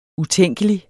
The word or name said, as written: utænkelig